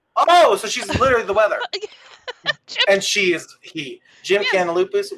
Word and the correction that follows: Jim Cantore: What would you say? – Jim Cantori. – Jim Cantoria. – Yes. – Cantori. Yeah. I keep adding a little extra syllable.